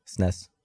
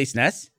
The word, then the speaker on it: SNES: How do you say the abbreviation? – SNES. – You do? You say SNES? – SNES.